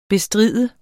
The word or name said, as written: bestride